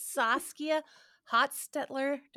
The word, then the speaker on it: Saskia Hostetler: This is Saskia Hostetler.